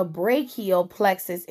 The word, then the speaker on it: brachial plexus: And you pronounce it as a brachial plexus injury, which I kind of had it right.